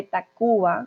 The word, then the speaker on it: Tacvba: Entonces, digo Café Tacuba con una U, sin embargo, se escribe con V, para que no piensen que escribí mal la U, simplemente es la forma en como la banda se hace llamar.